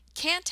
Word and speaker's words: can't: Can't, however, has a longer vowel sound, which is pronounced more like the vowel you hear in the word cat. Can't, can't, can't.